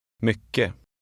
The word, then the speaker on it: mycket: In Swedish, a G in the middle of a word is often silent, so is a final T. With this in mind, read number 4. – Mycket.